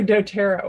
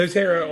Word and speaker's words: doTERRA: Oh, doTERRA oils. – doTERRA oils.